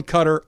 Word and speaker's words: Qatar: David Remnick, who hosts the New Yorker Radio Hour, he's going Cutter.